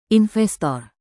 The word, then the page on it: investor: indonesian